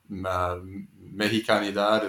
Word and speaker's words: Mexicanidad: So I wonder if you can talk a little bit about, you know, José Nápoles and Mexicanidad. Is that how you pronounce that? – Yes, word? – That's a very good pronunciation. Yes.